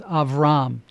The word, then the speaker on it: Avram: And keep in mind that when we first meet this man, which we know today as Abraham, his name was Abram, or in Hebrew it might have been pronounced Avram.